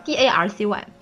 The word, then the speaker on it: Darcy: 嗯，然后下一条留言是 Mr. Dars Dars D A R C Y，我也不知道这个词怎么读。